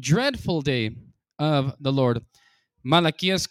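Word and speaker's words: Malaquías: Malachias, capítulo 4.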